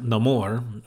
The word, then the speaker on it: Namor: And you learn, well, it's pronounced Namor, which he gets, you find out in his backstory that, if you speak any kind of Spanish, I think amor is like love.